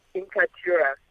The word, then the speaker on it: Inkaturah: I hope I'm pronouncing it right. – Yeah, very much so. Inkaturah.